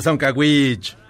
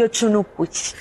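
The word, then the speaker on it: Dzoncauich: La D no suena, tendría que ser Soncaguich.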